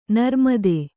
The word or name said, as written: ನಿರ್ಮದೆ